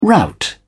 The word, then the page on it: rout: British English